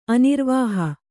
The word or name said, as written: ಅನಿರ್ವಾಹ